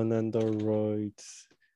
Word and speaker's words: Nendoroid: They had a Nando droid. I think that's how you say it, right? Nando? – I use Nendroids. – No, Nendoroids. Nendoroid. There you go.